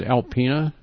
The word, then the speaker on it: Alpena: The town, the name of it is Alpena. – So how do you spell that? – A-L-P-E-N-A.